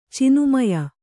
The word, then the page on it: ಚಿನುಮಯ: cinumaya